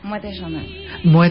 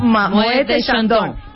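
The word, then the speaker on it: Moët Chandon: Di cómo se pronuncia propiamente. Mueet Shandong. – de Shandong. – Moët Shandong.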